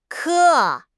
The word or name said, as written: ke1